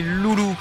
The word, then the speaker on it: Lulu: C'est les Loulous. Ça s'écrit L-U-L-U avec les accents, mais ça se prononce Loulou.